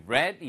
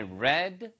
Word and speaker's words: read: Right, I read your mind. 이게 read가 아니라 읽은 거니까 I read your mind. 만약에 read라고 발음하시면 항상 마음을 읽을 줄 안다는 이야기겠죠?